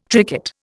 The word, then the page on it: tricot: Indian